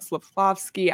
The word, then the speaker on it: Slafkovský: I'm sure we'll spend a whole episode talking about Slafkovský.